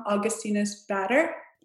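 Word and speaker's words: Augustinus Bader: I guess I would have to say and correct me if I say this wrong, Augustinus Batter. – Yes. Did I say it wrong? No, that's perfect.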